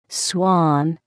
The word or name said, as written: swan